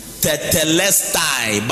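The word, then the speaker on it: TETELESTAI (τετέλεσται): The word or the sentence, it is finished in this statement or this scripture, is from the word TETELESTAI, which is pronounced TETELESTAI, but that is not how it is spelled.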